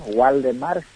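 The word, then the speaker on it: Waldemar: Yo soy Valdemar, W, Valdemar Schieder, y SCHW se escribe mi apellido.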